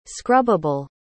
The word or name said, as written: scrubbable